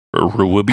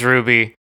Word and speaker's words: RWBY: He sang for the animated series RWBY, which might be pronounced Ruby. – I think it is Ruby.